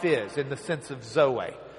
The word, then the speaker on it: zoe: but he uses the word zoe. Zoe. Zeta, Omicron, Eta. That A sound looks like our N with a tail. But it's a long E, in a sense. It's the eta. So, zoe.